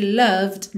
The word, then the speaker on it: loved: Primeiro, though, T-H-O-U-G-H, though, ok? I've loved you, atenção que o E de loved não é pronunciado, ok?